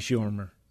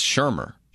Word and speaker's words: Shurmur: I guess I'm not sure why they were paying Shurmur. – It's Shurmur. – Shurmur, yeah, sorry, I mispronounced it.